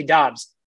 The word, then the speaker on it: Doubs: It's not Dubs. It's not Dubs. It's actually Dobbs. It's spelled D-O-U-B-S, but it's pronounced D-O-B-B-S.